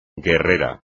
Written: guerrera

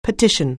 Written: petition

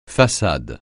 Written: façade

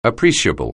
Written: appreciable

